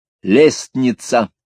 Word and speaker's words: лестница: Как ты, к примеру, произносишь слово «лестница»? «Лестница», — не задумываясь, сказал Вадим. «Вот, слышь, одну букву ты пропускаешь, а слово «перстчатка»?» «Такого слова нет, есть перчатка, а вначале была перстчатка.